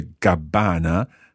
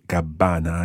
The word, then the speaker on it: Gabbana: And notice there, the Gabbana has got a double B but a single N. Listen to the pronunciation of Gabbana again. – Gabbana. – Gabbana.